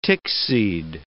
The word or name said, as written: tickseed